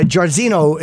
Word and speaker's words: Jairzinho: I want to make sure I'm saying his name right. Uh, Jairzinho is how you say his name.